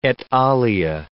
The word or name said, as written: et alia